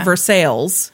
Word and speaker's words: Versailles: In Missouri, they pronounce the L in everything. They call it Versailles, Missouri.